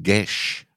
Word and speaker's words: Gecs: It's pronounced Gesh.